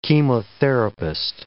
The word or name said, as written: chemotherapist